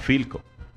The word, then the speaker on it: Pilco: Se escribe con P, se pronuncia con F, así como Pilco. No se dice Pilco, se dice Filco.